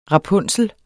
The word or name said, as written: rapunsel